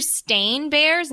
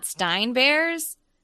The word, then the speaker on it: Berenstain: Because they're Stain Bears, not Stein Bears. – Yeah, everybody thinks it's spelled Bear in Stain Bears, like B-E-A-R-S. But it's the B-E-R-E-N-S-T-A-I-N. – Stain. – Yeah.